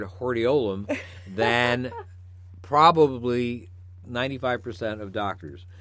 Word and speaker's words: hordeolum: A stye, interestingly enough, a stye is also known as a hordeolium. – Hordeolium. – I don't know if you knew that. Or hordeolum, actually.